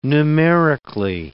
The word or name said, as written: numerically